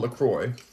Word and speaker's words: LaCroix: My sister said it's called LaCroix, so I gotta pronounce it the right way, I guess.